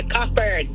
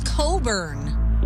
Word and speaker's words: Cockburn: Cockburn. Cockburn. – There you go. It's Coburn. – But spelt exactly the way you said it the first time.